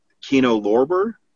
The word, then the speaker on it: Kino Lorber: Hang on a sec. What'd you say? – Kino Lorber? – Oh my God. You said it right. – Oh, I was like, oh, I've forgotten what it sounds like when someone actually pronounces it right.